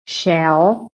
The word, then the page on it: SHELL: American English